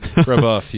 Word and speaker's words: Brèbeuf: Did I say your last name right? – Brèbeuf. You got it.